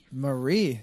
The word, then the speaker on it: Marie: Marie. – Yeah. – You got to keep it two syllables.